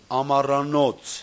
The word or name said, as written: Amaranoc